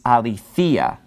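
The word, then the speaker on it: aletheia (ἀληθείᾳ): So because he loves him in the truth and it's following the preposition en, it's in the dative case, so it is aletheia. So it's pronounced a little bit differently because the accent is falling on this later syllable.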